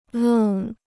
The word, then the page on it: 愣: lèng